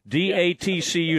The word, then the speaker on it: DATCU: It's D-A-T-C-U, which is weird because TCU.